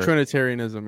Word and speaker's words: Trinitarianism: So you're saying that like am I saying it right? Trinitarianism or Trinitarianism, yeah.